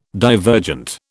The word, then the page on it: divergent: British English